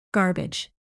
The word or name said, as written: garbage